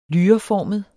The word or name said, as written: lyreformet